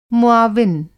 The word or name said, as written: मुआविन